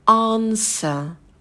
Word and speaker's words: answer: И вот важно не произносить звук «б». Частая ошибка — говорить answer. Нет, нет, нет, а W не произносится. Это будет answer.